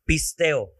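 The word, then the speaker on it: pisteo (πιστεύω): Pero la U, cuando se pronuncia esa palabra, la U no suena. Así que es pisteo o pisteo, Sí, porque lleva un acento.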